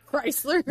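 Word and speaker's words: Kreischer: How do you say Bert's last name? – Crusher. Crusher? – Yeah. – Bert, no shirt. Chrysler. – Chrysler? – Yes, absolutely.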